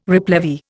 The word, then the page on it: replevy: Indian